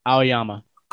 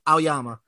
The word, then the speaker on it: Aoyama: Yuga, I don't know how to say it. Yuga Aoyama? – Aoyama. – Aoyama.